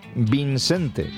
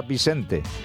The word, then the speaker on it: Vincente: Lo he dicho bien, Vincente. Yo también creía que era Vicente, o Vicente al menos. Pues no, hay una N ahí de por medio.